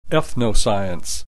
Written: ethnoscience

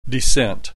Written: descent

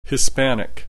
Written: Hispanic

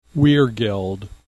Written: weregeld

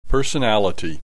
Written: personality

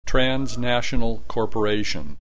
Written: transnational corporation

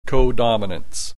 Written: codominance

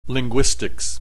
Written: linguistics

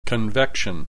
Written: convection

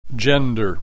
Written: gender

